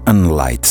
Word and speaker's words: N-Lite: Questi sono tre ragazzi da tenere d'occhio, si chiamano Unlight, scritto N-Lite. Si pronuncia come in luce in inglese, però si scrive così ovviamente.